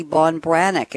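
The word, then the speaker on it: Ban Breathnach: The author's name is Sarah, and I'll pronounce it correctly, Bonbranek. If you try to spell it, you'll never find it. It's B-A-N, and last name is B-R-E-A-T-H, like breath, with N-A-C-H on the end.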